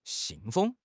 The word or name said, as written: xin4 feng1